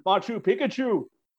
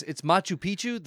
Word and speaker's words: Machu Picchu: Stanley, your destiny is to go to Peru and battle the mighty Machu Picchu. – Odin, I think you may be mispronouncing it. It's Machu Picchu.